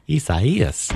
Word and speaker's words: Isaias: Isaias are headed our way. Did I say that right? – Isaias. – Okay. – I guess you did, yeah.